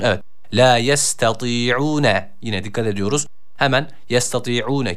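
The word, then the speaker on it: La yestati'une (لَا يَسْتَطِيعُونَ): Öncelikle tı harfine dikkat ediyoruz. I arası bir sesle bir elif miktarı uzatıyoruz. Kalın bir harftir. – La yestati'une.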